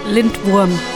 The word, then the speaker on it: Lindwurm: Up next is a band called Lindworm. Did I pronounce that correctly? – Lindworm, yeah.